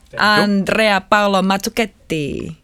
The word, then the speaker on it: Andrea Paolo Mazzucchetti: Andrea Paolo Mazzucchetti.